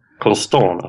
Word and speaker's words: Constant: Eh, Milans eminente vänsterback Kevin Constant, som vi kallar honom i den här podcasten. – Constant alltså? Ah, okay. Franskt yeah. uttal.